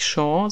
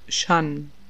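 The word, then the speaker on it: Jeanne: Also es ist ja nicht Sean, sondern Shan.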